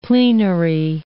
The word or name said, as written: plenary